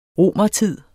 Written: romertid

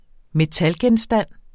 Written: metalgenstand

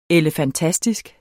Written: elefantastisk

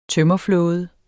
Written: tømmerflåde

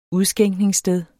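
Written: udskænkningssted

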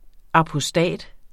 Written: apostat

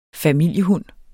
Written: familiehund

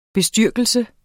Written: bestyrkelse

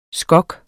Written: skok

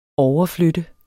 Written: overflytte

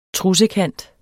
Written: trussekant

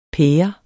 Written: pære

